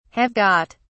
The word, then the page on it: have got: British English